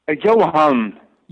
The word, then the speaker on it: Johan: Johan.